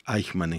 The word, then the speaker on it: Eichmann: es por supuesto Eichmann en Jerusalén, que es donde, donde gracias Dani Doch, no te puedo, no puedo creer lo que me decís, ese elogio dice que pronuncié perfecto.